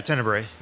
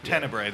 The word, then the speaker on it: Tenebre: Yeah, he was in, Tenebre? Am I pronouncing that right? – Tenebre. – Tenebre, that's it.